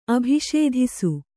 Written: ಅಭಿಷೇಧಿಸು

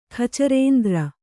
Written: ಖಚರೇಂದ್ರ

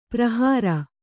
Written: ಪರಿಹರ

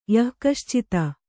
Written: ಯಃಕಶ್ಚಿತ್

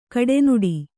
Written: ಕೆಡೆನುಡಿ

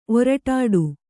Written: ಒರಟಾಡು

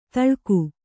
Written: ಥಳಕು

